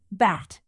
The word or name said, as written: bat